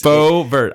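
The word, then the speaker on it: Boebert: I cannot say Bobert. it. It's Bovert. I want to say Brobert. Two syllables.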